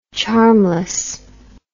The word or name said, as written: charmlessness